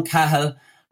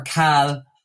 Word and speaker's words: Cahill: I'm going to start with Owen Cahill, or Cal, whichever he wants to go with. Cal is the more Irish pronunciation of it.